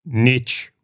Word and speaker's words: niche: And to put it on a bumper sticker, as our friend Keith would say, niche rhymes with rich for a reason.